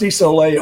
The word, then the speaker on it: Isole: And it's pronounced Isole only because I was living in Hawaii at the time. And in the Hawaiian language, that's the way it's pronounced.